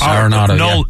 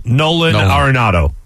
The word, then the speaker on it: Arenado: Nelson Arenado? Am I saying that? Yes, Arenado. – Nolan Arenado.